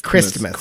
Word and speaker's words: Christmas: I like to pronounce the T. – Christmas.